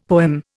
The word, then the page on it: poem: Indian